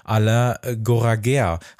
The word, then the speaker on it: Alain Goraguer: Alain Goraguer. Habe ich doch richtig ausgesprochen, Albert, ne? – Ja. Korrekt.